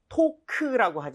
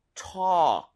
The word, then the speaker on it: talk: 그래서 발음할 때 근데 토크라고 하지 말아주시고요. – 네. – A의 강세를 주시고, 그리고 입모양을 좀 벌려서 톡보다 오히려 talk 이렇게 Talk.